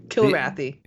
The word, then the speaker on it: Kilrathi: But basically, there's a war going on between the humans and the Kalrathi. Am I saying that right? Kalrathi? – I've already forgotten what they're called. Kilrathi.